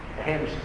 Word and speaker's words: Reims: Reims, pronunciou? Receber a coroa em Reims.